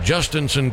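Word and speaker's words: Jhostynxon: With this one, Justinson Garcia, if I gave you 1,000 chances, you could never spell it right.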